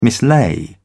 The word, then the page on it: mislay: British English